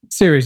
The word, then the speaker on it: Ceres: Ceres, right? – Ceres, yeah.